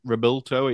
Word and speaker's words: Revuelto: And I had a good look at the uh, it's Revuelto. It's it's uh, supposed to be pronounced in Spanish,